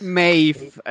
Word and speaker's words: Maeve: Dann ähm, sollten wir auch noch über unsere Antagonistin sprechen, nämlich, wie, wie spricht man sie aus? Königin Maeve? – Maeve. Also ich, hier wird sie Maeve ausgesprochen. Ich komme später dann nochmal zu der, ähm, wie heißt es auf Deutsch?